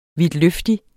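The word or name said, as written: VIDTLØFTIG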